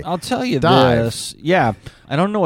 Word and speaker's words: DIIV: I say it Dive because it used to be called Dive. So okay. – That's what I say. – It's spelled D-I-I-V.